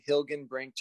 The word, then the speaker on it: Hilgenbrinck: Nailed the last name, Hilgenbrinck, just like it's spelled. Everybody hesitates before they say it, but it's not too scary once you do.